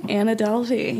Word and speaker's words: Anna Delvey: Anna Delvey.